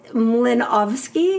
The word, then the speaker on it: Milanowski: It's pronounced Mlinowski because it's a Polish double.